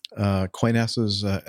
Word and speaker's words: Qantas: Just some feedback regarding Qantas's accident record. I think that's the way you say that. – Yes, that's the proper pronunciation.